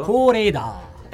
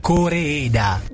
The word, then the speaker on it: Koreeda: Koreeda. – Ecco, perché l'anno scorso ci dicesti Koreeda.